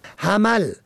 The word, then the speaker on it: حمل: حمل به فتح و میم ماه اول سال شمسی است که شماری آن را ماه حمل می خوانند که سخت نادرست است.